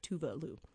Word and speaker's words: Tove Lo: The correct pronunciation, I know I sound pretentious, but I'm just trying to be respectful of how it's pronounced. She's Swedish, so it's Tove Lo.